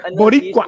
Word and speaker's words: Boricua: Man, what kind of pronunciation? – Boricua. – There you go.